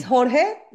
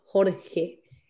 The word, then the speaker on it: Jorge: His name is Jorge, like George, but in Spanish, George is Jorge.